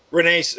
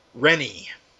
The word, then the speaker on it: Rennie: I've been butchering his name for nigh on 10 years, and apparently it's pronounced Gordon Rennie. – Yes. – Not Rene with a flourish, as I tend to do.